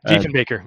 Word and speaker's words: Diefenbaker: And then Kevin says, my grandfather was a personal friend of, I might mispronounce this, Diefenbaker? Diefenbaker? – Diefenbaker, yes.